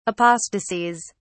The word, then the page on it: apostasies: British English